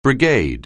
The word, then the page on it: brigade: anglais